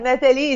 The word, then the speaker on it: Nathalie: Nathalie, it's like Nathalie is like the French way,